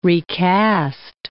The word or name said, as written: recast